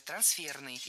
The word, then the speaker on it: трансферный: Трансферное, неверно. Правильно, трансферное, трансферный. И здесь опять же шпаргалка, исходное существительное «трансфер». Оно французского происхождения, имеет ударение на втором, то есть последнем слоге. Трансфер. И такое же ударение сохраняется в прилагательном. Трансферный.